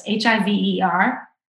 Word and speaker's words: hiver: H I V E R.